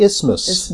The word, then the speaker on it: isthmus: You don't pronounce that TH, The isthmus.